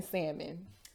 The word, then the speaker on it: salmon: He pronounced the L in salmon.